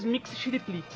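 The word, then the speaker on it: Mxyzptlk: Mas que porra Não, na verdade, não tem vogal no nome dele. – Eu sei quem é. – De letras aí, a pronúncia é mais ou menos Mix Chiriplix.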